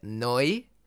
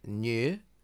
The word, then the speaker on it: Neu: Neu, often mispronounced new, N-E-U exclamation mark.